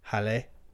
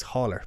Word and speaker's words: Haller: But I think the talking point is the new signing from Germany, but he is French, Halle. So yeah. I think that's how you pronounce it. It looks Haller, but I think it's Halle.